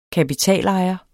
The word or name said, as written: kapitalejer